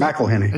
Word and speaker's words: McIlhenny: And you mentioned this name, Edmund McElhenney. Is that how we pronounce it? – McElhenney.